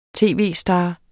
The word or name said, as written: tv-star